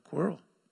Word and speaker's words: quarrel: He says, don't quarrel. Don't quarrel. Am I pronouncing that right? It's like squirrel, quarrel. Quarrel. It's got an A in it. Okay. Don't quarrel. Q-U-A.